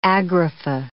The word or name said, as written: agrapha